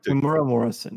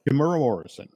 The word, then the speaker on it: Temuera Morrison: I mean, but for me, you know, when I look at the news, like, for example, and correct me if I'm getting the pronunciation of the name wrong here, Temuera Morrison, is that correct? – Temuera Morrison, yep. – Temuera Morrison, okay.